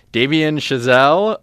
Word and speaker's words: Damien Chazelle: Damien Chazelle, La La Land.